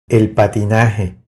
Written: El patinaje

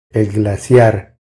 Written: El glaciar

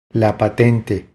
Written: La patente

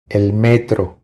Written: El metro

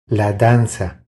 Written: La danza

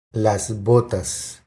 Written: Las botas

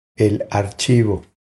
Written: El archivo

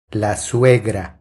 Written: La suegra

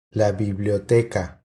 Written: La biblioteca

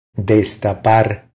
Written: Destapar

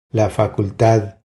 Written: La facultad

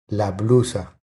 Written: La blusa

